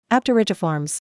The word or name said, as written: Apterygiformes